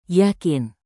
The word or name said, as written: yakin